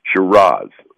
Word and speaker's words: Shiraz: And the nomenclature difference really comes from Australia, where the Syrah grape, they pronounce Shiraz, S-H-I-R-A-Z.